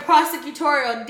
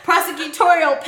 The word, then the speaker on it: prosecutorial: And this is already trouble that she has with black voters, with her persecutorial past, mm-hmm. if I said that correctly. Yeah. – Prosecutorial. – Damn, I've had a lot of wine. prosecutorial